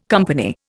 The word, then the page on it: company: Indian